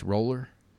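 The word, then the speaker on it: Roullier: And Spencer Long is injured, so we started a rookie, Chase Rollier, is that how you say it? I'm calling him. Where is he lining up? – Chase Roller.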